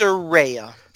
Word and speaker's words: Saraya: And Saraya, am I saying it right? Is it Saraya or Saraya? – It's Saraya. – Saraya, yeah. Yes.